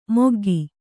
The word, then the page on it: ಮೊಗ್ಗಿ: moggi